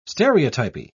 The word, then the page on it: stereotypy: British English